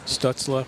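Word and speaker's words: Stützle: Connor Brown, Stutzla. I hope I said that right, guys. – Yep. Yeah, yeah.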